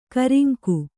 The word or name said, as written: ಕೂರಂಗಿ